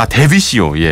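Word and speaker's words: Dvicio: 어, 드비시오의 이아 데비시오 예. 아 뭐, 처음부터 그러니까 D V I C I O입니다. 그러니까 이게 든지 다 데, 데비시오의 네. 가시 휴마노스 그리고 아, 알바로 솔레르의 소피아, 뭐, 안토니오 까르모나의 아, 달레루즈 그리고 루이스 폰시의 데스파시토.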